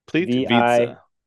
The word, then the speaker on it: Plitvice: Plitvice. P-L-I-T. – Plitvice. Like pizza. Plitvice. Yeah. Like Plitvice.